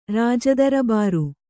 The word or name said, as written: ರಾಜದರಬಾರು